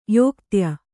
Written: ಯೋಕ್ತ್ಯ

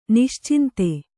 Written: ನಿಶ್ಚಿಂತೆ

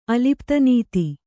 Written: ಅಲಿಪ್ತನೀತಿ

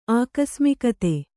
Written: ಆಕಸ್ಮಿಕತೆ

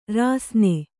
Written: ರಾಸನಾ

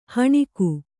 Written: ಹೆಣಗು

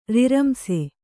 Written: ರಿರಂಸೆ

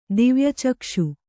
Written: ದಿವ್ಯಚಕ್ಷು

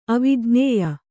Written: ಅವಿಜ್ಞೇಯ

